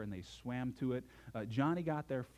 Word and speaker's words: Joni: Her name is spelled J-O-N-I and pronounced Johnny, and you may know her because she's been a popular Christian speaker in the past.